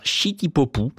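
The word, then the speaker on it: shitty-popu (シティ・ポップ): Et Stéphanie Ivanovic nous indique que c'est un classique d'un genre musical propre au Japon, la city pop, qu'il prononce là-bas shitty-popu,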